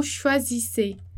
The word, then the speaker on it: choisissez: Então fica vous choisissez, algo que como se fosse desse modo para pronúncia, né? Vous choisissez, choisissez, choisissez. Então eu coloquei ali entre barras o modo como ficaria a pronúncia para você entender. Então os pormenores, né? O OI que vai fazer som de UA, um S que vai fazer som de Z e dois S que vai fazer som de S.